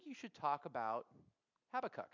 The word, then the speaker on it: Habakkuk: And according to YouTube, it was Habakkuk. So today we're going with Habakkuk.